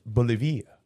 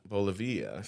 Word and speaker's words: Bolivia: Pronounced Bolivia. – Bolivia.